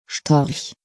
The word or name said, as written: Storch